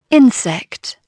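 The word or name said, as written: insect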